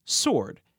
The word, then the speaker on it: sword: I can only think of two other words in the entire English language that have a silent standalone W. They are answer, A-N-S-W-E-R, and sword, S-W-O-R-D.